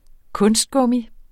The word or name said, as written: kunstgummi